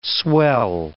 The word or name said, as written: swell